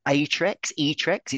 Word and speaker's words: AETREX: And I believe that prefab they get given is pronounced Atrex. It's AETREX.